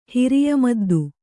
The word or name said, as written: ಹಿರಿಯಮದ್ದು